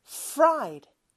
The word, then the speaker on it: fried: 자, 그럼 붙여서 fried, fried. R 발음이 좀더 강하게 나죠? 그렇게 연습해 주시면 돼요. 그래서 영어로는 후라이드가 아니고 fried이다.